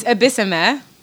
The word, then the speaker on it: Ebisiemen: It's Ebisiemen. The N is silent.